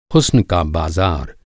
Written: हुस्न

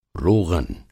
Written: रोग़न

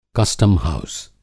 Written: कस्टम हाउस

